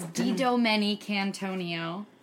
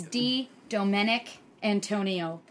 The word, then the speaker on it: Didomenicantonio: It's Didomenicantonio. If you break it up, it's Didomenicantonio put together.